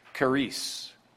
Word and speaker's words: charis: In Mounces, again, kind of a modern version of Vines that we've used over the years, but the word grace is charis. It's spelled with a C as in charisma. You pronounce it with a K, but it's spelled as charisma would be spelled, but it means grace or favor.